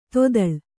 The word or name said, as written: ತೊದಳ್